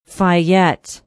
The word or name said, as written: Fayette